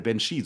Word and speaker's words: Banshee: Das ist der Banshee, so wird er ausgesprochen.